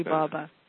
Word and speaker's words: Sai Baba: Now, I'll let you pronounce the person's name who wrote that. You know how to say it. – Sai Baba. – Exactly.